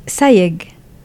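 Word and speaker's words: Sayegh: Sayeg.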